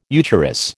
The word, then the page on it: eucharis: American English